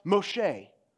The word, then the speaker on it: Moshe: In Hebrew, it's pronounced Moshe, which means drawn out. But in Egyptian, it's pronounced Moses, which means son.